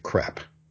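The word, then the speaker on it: crêpe: But a French person would say crepe.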